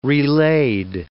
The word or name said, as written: relaid